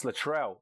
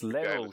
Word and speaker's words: Latrell: Why can't anyone say his name? It's Latrell, as in Latrell Sprewell. What's all this lateral shit?